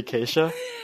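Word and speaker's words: Kesha: I had to go search it up just a while ago and find out it wasn't actually pronounced K$ha, but it was actually Keisha.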